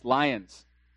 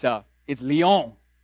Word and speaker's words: Lyon: It's not pronounced lions. It's Lyon.